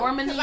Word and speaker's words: Normani: Normani.